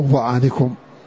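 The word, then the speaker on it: ظعنكم: وقد جاءت في سورة النحل في الآية 80، آية 80 تجد فيها لفظ ضعنكم أو ضعنكم حسب القراءة مرسومة بالضاء، ولا تنطق إلا بالضاء، وضعنكم أو ضعنكم، ولا يجوز بدلها بضعنكم، لا يجوز هذا، ولا وضع لا لا يجوز، ويوم ضعنكم أو ويوم ضعنكم.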